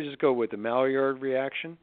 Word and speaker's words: Maillard: Well, I just didn't pronounce it correctly because it's really a French word. And I was giving a lecture. I was giving a lecture a couple of years ago, and I said the Maillard reaction. And this Frenchman in the back of the room says, "Monsieur, it is the Maillard." – Maillard. – So that's the way it should be pronounced.